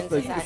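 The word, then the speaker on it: Ntozake: Enzo Nozuki? – No, oh, oh, Inzaki. Say it right.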